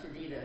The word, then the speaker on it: Jedidiah: Jedidiah is the way I pronounce it.